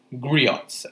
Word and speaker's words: griots: is usually told by these people known as griots. I think I pronounced that right. It's spelled G-R-I-O-T-S.